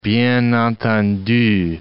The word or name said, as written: bien entendu